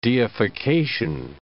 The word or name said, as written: deification